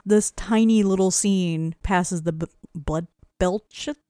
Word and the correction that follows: Bechdel: How do you pronounce that? – Bechdel. – Bechdel, thank you.